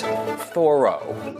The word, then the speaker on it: Thoreau: Well, first of all, he'd say, hey, fella, it's Thoreau.